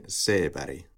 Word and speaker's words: Seberg: So I believe the first name should be pronounced in an American way. And then the Swedish surname I'm just going to pronounce in the Swedish way. So Gene Seberg.